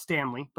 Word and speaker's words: Stantley: And I know it might be pronounced Stanley, but I'm pronouncing it Stantley because it's spelled wrong.